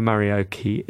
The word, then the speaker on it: Marioki: Now, in line with everyone's expectation and pronunciation, Marioki, like karaoke with an M, modded karaoke, and that's built into the logo.